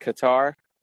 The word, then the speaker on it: Qatar: Am I saying that right? Is it Qatar? Okay, that's what I thought.